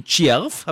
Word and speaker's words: Tschierv: Diesmal in Tschierv, habe ich das richtig ausgesprochen? – Ja, ich glaube schon.